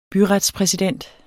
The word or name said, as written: byretspræsident